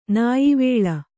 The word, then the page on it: ನ್ಯಾವಳ: Kannada